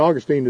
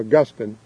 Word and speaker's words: Augustine: You know, people say St. Augustine is in Florida. Really pronounce his name Augustine.